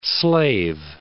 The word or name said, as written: Lesser Slave Lake